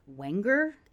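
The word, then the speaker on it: Wanger: Because the producer, Walter Wenger, apparently it rhymes with danger.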